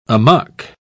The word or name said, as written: amuck